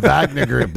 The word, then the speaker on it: Wagner: Wagner Group. – Wagner Group. – It's just with a W. – It's German, dude. It's V. Pronounced V. – My bad. The Wagner Group there, Mein Führer, right.